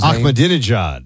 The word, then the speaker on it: Ahmadinejad: Mahmoud Ahmadinejad or whatever you pronounce his Ahmadinejad.